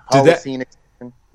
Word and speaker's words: Holocene Extinction: And you guys just – Holocene Extinction, did I say that correctly, by the way? – Yes, yeah. Holocene Extinction.